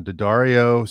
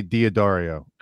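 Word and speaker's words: D'Addario: I usually say Diodario. – Everyone says Diodario, but that is not the correct.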